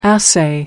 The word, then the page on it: assay: American English